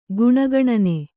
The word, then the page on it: ಗುಣಗಣನೆ: guṇa gaṇane; Kannada